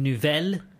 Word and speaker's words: nouvelle: Nouvelle and nouveau.